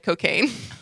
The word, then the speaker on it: Cokagne: The Diet Cocaine. But it's spelled C-O-K-A-G-N-E. Like champagne.